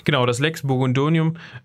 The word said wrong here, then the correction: Burgundionum: Ähm, Habe ich es richtig ausgesprochen? Burgundionum! Äh, danke, Michi. Burgundionum, Lex Burgundionum ähm, finden sich auch Verweise ähm, auf Personen, die man mit König Gunther, Gernot und Giselher verknüpfen könnte.